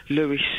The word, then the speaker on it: Lewes: Well, we'll start off with a nice, interesting one with Leona Lewis. – Leona Lewis! – Okay, what's the interesting Leona Lewis fact? What's the fact? – Well, Lewis is like a big town in East Sussex. L-E-W-E-S, Lewis.